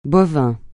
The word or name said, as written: bovin